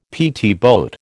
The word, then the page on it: PT boat: American English